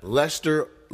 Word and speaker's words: Leicester: Leicester. Leicester. And it's spelled L-E-I-C-E-S-T-E-R. That's why I'm struggling with it, because it's spelled like Leicester.